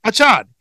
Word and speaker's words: Achan: He said his last name was Achan. He said everybody was saying it wrong.